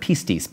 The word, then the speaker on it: pistis: The Greek word behind it is the word pistis, P-I-S-T-I-S is how you'd spell it in English, pistis, pistis.